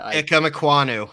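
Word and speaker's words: Ikem Ekwonu: Ikemekwunnu out of North Carolina State.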